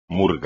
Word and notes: murga: espagnol

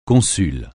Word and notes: consul: francais

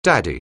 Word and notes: daddy: anglais

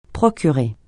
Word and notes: procurer: francais

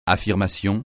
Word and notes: affirmation: French

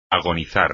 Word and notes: agonizar: espagnol

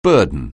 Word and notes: burden: anglais

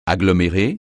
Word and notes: agglomérer: French

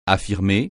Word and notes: affirmer: French

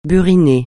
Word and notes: buriné: francais

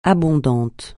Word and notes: abondant: francais

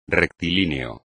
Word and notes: rectilíneo: espagnol

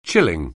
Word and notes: chilling: anglais

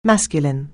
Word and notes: masculine: anglais

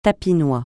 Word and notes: tapinois: francais

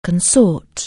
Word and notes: consort: anglais

